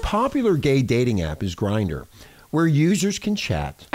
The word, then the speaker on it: Grindr: Grindr.